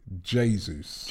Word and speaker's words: Jesus: Some people say Jesus. It's actually Jesus. – Jesus. – That is what a Brazilian guy told me. That in Brazil, it is pronounced Jesus.